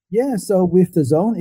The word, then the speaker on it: DAZN: It's pronounced the zone. You know how in sports, there's this like a metaphor of you're in the zone where, you know, you're completely focused. Now you're at the top of the game. That's what the DAZN is supposed to pronounce, the zone. But it's so bizarre. Most people in the company pronounce it wrong. We have a lot of engineers call it dozen. – Okay. – But yeah, don't worry. Yeah. It's called the zone.